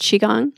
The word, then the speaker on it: Qigong: And it's pronounced Qigong? – Yeah. The Qi is another way to spell Qi.